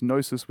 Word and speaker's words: Gnosis: It's Gnosis with a silent G, I guess. Because there's no way you're going to pronounce that.